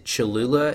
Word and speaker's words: Cholula: Is it Cholula? Is that how you pronounce it? – Yes.